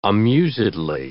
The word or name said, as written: amusedly